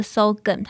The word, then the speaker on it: Sogumm: 今天我要介绍的这首歌呢，它是来自于一个新生代的歌手，也就是我们 LMG 新迁入的一个 R&B 女歌手，叫做 Sogan。她的新歌，那 Sogan 这个意思是什么呢？它其实是跟韩文的“ 盐” 这个字是一样的发音 Sogan。